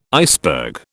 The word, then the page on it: iceberg: British English